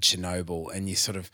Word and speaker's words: Chernobyl: So I can't Chernobyl, even pronounce guys. It. Well, you got it right.